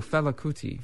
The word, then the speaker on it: Fela Kuti: Futu Kuti. – Oh, Fela Kuti.